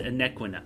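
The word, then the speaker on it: Anequina: Every piece of Aniquina, I keep mispronouncing, it's Aniquina.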